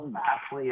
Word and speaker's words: Vassell: Is it Vassell? Vassell? – Vassell, yeah, Devin Vassell or whatever.